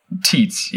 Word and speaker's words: Tietz: Tits, yes. I mean, when it comes to German names and pronunciation, you should be very aware of how Christian Tietz and Stefan Kuntz are pronounced.